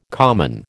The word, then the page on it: common: American English